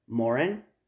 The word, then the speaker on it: Morin: Now, it's spelled like Morin, but they're French.